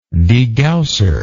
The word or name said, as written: degausser